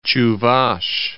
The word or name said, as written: Chuvash Republic